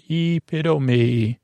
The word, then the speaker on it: epitome: That's from the Greek. Epitome. How do you say that? Pit-a-me. One reason is similar to Italian. All the vowels from Greek words are pronounced. No silent E's here. Epitome. Epitome. Epitome. Epitome. Indict. Yeah, you'll indict my spelling. Indict. I-N-D-I-C-T.